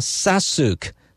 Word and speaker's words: Sasuke: M Sasuke 吗？Sasuke Sasuke M S A S U K E Sasuke 啊，这首歌叫做 GG Easy 啊。